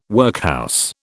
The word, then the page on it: workhouse: British English